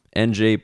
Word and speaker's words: Ng: NG pronounced Ng. Lock that in. Have that as your stock pronunciation.